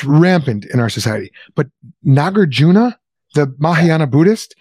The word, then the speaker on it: Nagarjuna: You know, is Nagarjuna really a guy, if I'm even pronouncing his name, Nagarjuna.